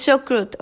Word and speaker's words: chucrut: El chucrut, chocrut o chocrut, ¿cómo se dice? La col fermentada, chocrut. Es chucrut ok.